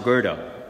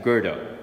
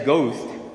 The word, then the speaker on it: Goethe: And so the first one he quotes is she, mispron- she mispronounced Goethe. That's how you say that, Goethe. If you said ghost, these individuals would have said, Sorry, I'm, um, please delete my phone number from your cell phone, please.